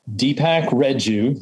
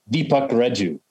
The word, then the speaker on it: Deepak Reju: Deepak Reju, that's who I am. That's apparently how I pronounce it. Though if I were Indian, I'd have a heavy accent like Deepak Reju, something like that.